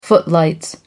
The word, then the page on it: footlights: American English